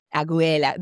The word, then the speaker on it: Aguilera: Aguilera. They say it properly in Chile. I heard her perform recently. They said it properly. I was like, that's how you're supposed to say that last name.